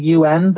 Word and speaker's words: Yuan: So this is the one spelled Y-U-A-N, and it's pronounced like the United Nations, like the U-N, but just say it quickly together, one word, U-N.